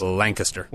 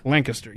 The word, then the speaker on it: Lancaster: Lancaster. – Lancaster, yes.